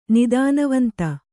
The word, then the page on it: ನಿದಾನವಂತ: nidānavanta